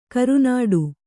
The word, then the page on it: ಕರುನಾಡು: karunāḍu